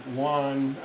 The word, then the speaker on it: Juan: Like Juan, the name Juan, J-U-A-N, is pronounced, the J is pronounced with a Y sound in English, reflecting the pronunciation in Spanish.